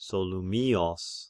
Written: Solymios

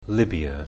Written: Libya

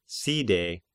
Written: Side

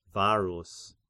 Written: Varus

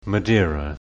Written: Gadir

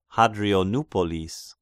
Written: Hadrianopolis

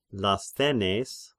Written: Lasthenes

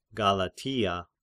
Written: Galatia

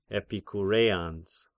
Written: Epikureans

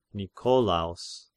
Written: Nikolaos

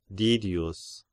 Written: Didius

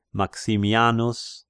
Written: Maximianus

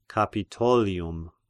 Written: Capitolium

